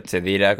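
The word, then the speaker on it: Cheddira: Sí, que Dira con CH, Chedira, como se pronuncia, en Marruecos.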